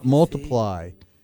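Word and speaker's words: ×: On Christmas Day in the year 2014, Ed Sheeran was at number one on the UK album chart with X, pronounced Multiply.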